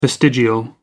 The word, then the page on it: vestigial: American English